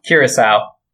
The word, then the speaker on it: Curaçao: Curaçao.